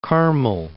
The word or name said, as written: Carmel, Mount